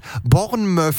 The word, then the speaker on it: Bournemouth: Bournemouth, ich hab's gesehen, man hat's dir in die Aussprachehilfe geschrieben, da steht Bournemouth, M-Ö-Doppel-F.